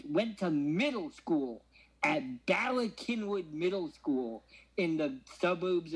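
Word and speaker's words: Bala Cynwyd: Ballykinwood, Pennsylvania, which is spelled C-Y-N-W-Y-D because it was like fucking Welsh Quakers founded this town in Pennsylvania.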